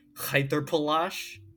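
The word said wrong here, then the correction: Reiterpallasch: You have to do it with the back of the throat. That's what the wiki says. It's Reiterpallash.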